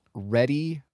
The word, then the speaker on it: Rhetty: So I've been watching this YouTube channel that got recommended to me, and it's called Ready for History. It is spelled R-H-E-T-T-Y, Ready for History.